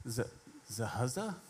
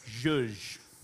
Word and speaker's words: zhuzh: Zahuzza? Zahuzza? Is that what it looks like? Believe it or not, you pronounce this zhuzh, zhuzh, zhuzh. Never would have guessed that.